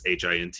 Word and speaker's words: Hintz: I want to say his last name's Hintz. I think that's the proper way to pronounce it. I know it's Hints.